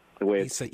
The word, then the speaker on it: Isaias: Isaias. – You don't think it would sound like Isaias the way it's spelled, but that's how it's pronounced.